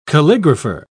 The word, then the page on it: calligrapher: British English